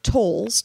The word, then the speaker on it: Towles: Written by Amor Towles, T-O-W-E-L-S, spelled like towels. That can't be right. L-E-S. Amor Towles.